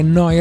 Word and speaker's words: Neu: Noi Radio. Neu Radio si scrive Neu, si pronuncia Noi e vuol dire nuova, nuova emittente urbana.